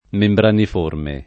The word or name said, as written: membraniforme